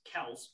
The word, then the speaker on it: Kelce: And I found out that their last name is actually pronounced Kelce because Jason Kelce did an interview where he said everybody's been pronouncing his name wrong.